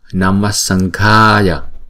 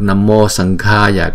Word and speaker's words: Namasanghaya: Chúng ta cũng phát âm từ phần cuốn họng của chúng ta, cho nên là chúng ta sẽ phát âm là Sanghaya, Namasanghaya. Hay là nếu mà đọc Namo Sanghaya cũng được. Nhưng mà nếu mà đọc theo đúng cái sandhi, luật sandhi của tiếng Phạn đó, thì chúng ta đọc là Namasanghaya. Namo Suvarna Bhasasya.